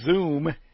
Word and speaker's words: Zume: Interesting, the company name Zoom, which of course sounds like, you know, Zoom Zoom, but it's spelled Z-U-M-E, not unlike the defunct Microsoft music player.